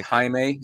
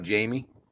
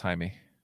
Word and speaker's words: Jaime: His name is Jaime. It's not Jamie. – No, he's Latino. It's Jaime.